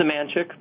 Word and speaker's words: Semanchik: It's Semanchik, but...